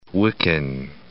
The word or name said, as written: Wiccan